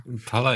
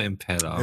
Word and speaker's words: Impala: Impala, Impala. – Impala, Impala geht beides.